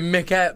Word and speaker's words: Michael: No, because the Swedish name would be Mikael. – Yeah, it's Mikael. – Yeah. – But it's spelt Michael. – But with a CH.